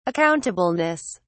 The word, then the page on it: accountableness: British English